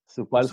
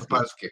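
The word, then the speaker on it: Sapolsky: איתן פייג' הוא פרוספקט של איבולב, של דייב אה, גפולסקי, אני תמיד שוכר איך מבטאים את השם שלו. סופלסקי. סופלסקי. גייב סופלסקי. בדיוק, גייב סופלסקי.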